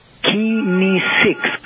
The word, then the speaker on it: kinesics: You know, body language technically is known as kinesics. It's pronounced kinesics.